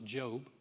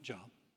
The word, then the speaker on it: Job: That's actually pronounced Job, not Job.